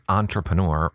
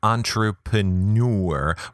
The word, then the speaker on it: entrepreneur: First, I had no idea that entrepreneur rhymes with manure. I pronounce it entrepreneur rhymes with door.